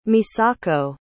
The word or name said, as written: misako